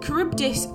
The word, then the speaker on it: Charybdis: It's pronounced Scylla, much like Scylla Black, but spelt S-C-Y-L-L-A, and Charybdis is kind of pronounced slightly like Cardi B, but also like the name Charis, but it's C-H-A-R-Y-B-D-I-S.